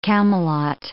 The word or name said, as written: Camelot